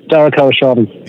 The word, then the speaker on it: Dara Khosrowshahi: Dara Khosrowshahi.